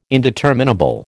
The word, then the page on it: indeterminable: American English